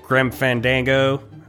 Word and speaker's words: Grim Fandango: Grim Fandango, I think I pronounced that right.